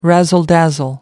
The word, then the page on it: razzle dazzle: American English